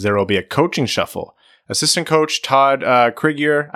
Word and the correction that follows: Krygier: Is it just Krieger? – Spell it. If it's the K-R-Y- one I'm thinking of, you said K-R-Y-G-I-E-R. – Krieger, yeah.